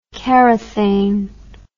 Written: Karathane

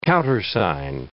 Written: countersign

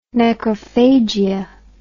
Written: necrophagia

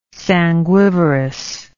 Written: sanguivorous

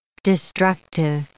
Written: destructive

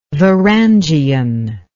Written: varangian

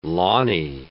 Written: lawny

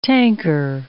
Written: tanker

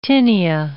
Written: tinea

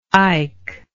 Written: ike

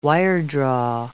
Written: wiredraw

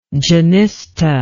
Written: genista

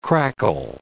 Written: crackle

